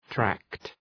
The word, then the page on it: tract: trækt